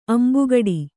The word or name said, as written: ಅಂಬುಗೂಡು